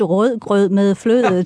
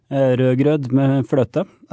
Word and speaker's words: rødgrød med fløde: I think probably the key phrase is a very famous dessert in Denmark, which is called rødgrød med fløde. And supposedly, during the war, they used rødgrød med fløde to kind of figure out who was a German spy.